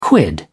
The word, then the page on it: quid: British English